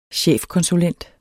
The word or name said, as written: chefkonsulent